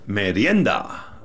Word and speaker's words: merienda: How do I say that? Merienda? – Merienda.